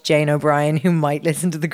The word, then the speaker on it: O'Brien: It's O-B-R-I-E-N. Is that Brian? – No, it's O'Brien.